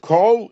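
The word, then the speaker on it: Kol (כל): When we say Tilim, chapter 87, verse 7, the Pasuk is Vishorim Kicholim Kol Mayonai Boch. So the word Kol is pronounced with a comets.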